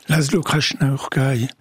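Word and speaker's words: László Krasznahorkai: Laszlo Krasznahorkai.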